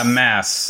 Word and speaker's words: Mads: You know it's pronounced mess, right? – You can get wet Yeah, mass.